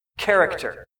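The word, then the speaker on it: Character (χαρακτήρ): The imprint, the word imprint in Greek is character. Say that. Character. That's literally the Greek word. That's how it's pronounced.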